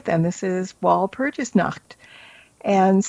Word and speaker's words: Walpurgisnacht: Okay, so Walpurgisnacht, that's how you say it. – Walpurgisnacht